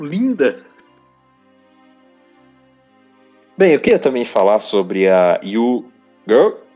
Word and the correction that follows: Yugiri: A Yu Girl, ela é Yugiri. a personagem. – yu yu Giri, droga. Sempre esqueço como pronuncia.